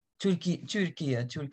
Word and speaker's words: Türkiye: Türkiye.